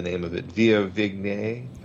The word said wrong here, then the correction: Viognier: Oh, Viognier.